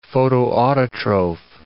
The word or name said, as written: photoautotroph